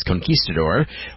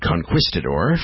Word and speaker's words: Conquistador: That is Conquistador, or as Gary Brooker mispronounces it, Conquistador, from 1972, on the Give Me Back My 70s show.